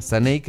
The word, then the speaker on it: Saneiz: Saneis, que se escribe, chiquillos, como les dijimos, S-A-N-E-I-Z. Saneis, que es Sandra Neira Zúñiga.